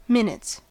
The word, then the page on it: minutes: American English